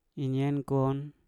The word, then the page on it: inyen kon: Mpur